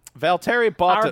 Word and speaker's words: Valtteri Bottas: our boy Valtteri Bottas. The official F1 driver of the full cast. – If you're going to say that he's ours, you have to say his name right. – Valtteri Bottas.